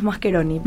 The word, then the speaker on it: Mascheroni: En italiano, o sea, si vamos a decir bien, es Mascheroni.